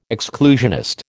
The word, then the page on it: exclusionist: American English